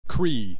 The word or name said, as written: Cree